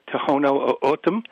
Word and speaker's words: Tohono O'odham: Well, there's a number of pronunciations, but they favor Tohono O'odham.